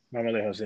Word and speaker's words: Marmolejos: That's how you pronounce it, right? Marmolejos? – Marmolejos, yes.